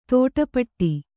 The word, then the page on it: ತಾಟಿಪೋಟಿ: Kannada